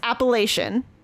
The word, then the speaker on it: Appalachian: So they put the pronunciation. It's Appalachian. I've heard Appalachia, whatever.